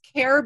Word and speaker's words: Caire: And so everybody, just so you know, it's Care Beauty and that's spelled C-A-I-R-E.